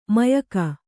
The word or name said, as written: ಮಾಯಕ